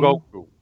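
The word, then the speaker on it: Hogyoku: It's H-O-G-Y-O-K-U. – Y-O-K-U? – Hogoku. – It's Y or G? – H-O-G-O-K-U.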